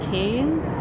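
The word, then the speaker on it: Cairns: someone told me it's pronounced Canes. You don't pronounce the R because they're Australian.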